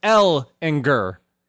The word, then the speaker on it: Ehlinger: It is L is the um, emphasis. It's Ehlinger. G U R. Oh, I win. I win. I had the correct Ehlinger. pronunciation. Ehlinger. All right, there we go.